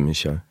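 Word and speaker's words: Michôd: So when you work with somebody like Michaud, is that how you say his name? – Yeah, David Michaud.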